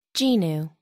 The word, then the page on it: genu: American English